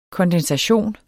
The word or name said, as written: kondensation